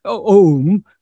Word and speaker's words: Om: So in other words, when we pronounce O, Om, we begin with our mouth open, allowing the air to pass out. Then we close the lips and finally come to M, which is the closed lip.